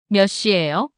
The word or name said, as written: 몇 시예요?